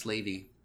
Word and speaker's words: Slavey: Also, I did look it up, and it is pronounced slavey. Slavey is an Athapascan word. It used to be pronounced, spelled with an E-Y, but they dropped the Y for reasons unknown. And now it says slave.